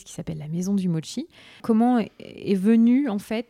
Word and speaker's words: mochi: Et c'est comme ça qu'on va arriver à parler des mochi. Alors, j'ai appris grâce à toi qu'on ne disait pas mochi, parce que moi, je les mange, mais je les prononce mochi. Et donc, c'est mochi.